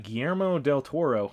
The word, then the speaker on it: Guillermo del Toro: All right, Guillermo del Toro. Man, I fucking nailed that name pronunciation that time.